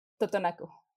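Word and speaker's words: Tutunakú: And the language is the Tutunacu. I'm probably saying that terribly wrong. How do you pronounce that? – Tutunacu.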